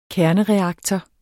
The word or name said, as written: kernereaktor